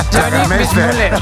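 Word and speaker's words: Johnny Weissmuller: Johnny Mismuller. Así lo pronunciamos.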